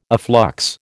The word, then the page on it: afflux: American English